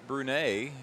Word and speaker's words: Brunet: David Brunet. He has reminded me that the T is silent. It's B-R-U-N-E-T.